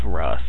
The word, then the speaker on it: Hruth: It's rough. The H is silent. It's yeah, cause are you sure? – Cause in Beowulf, the like one of the characters' names is like Hrothgar. It's kind of spelled the same with the H in mm-hmm. front, and you don't pronounce it.